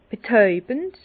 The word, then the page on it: betäubend: Bas-Rhin; Herrlisheim